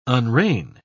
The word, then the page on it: unrein: British English